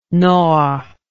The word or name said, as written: nord